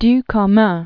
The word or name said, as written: Elie Ducommun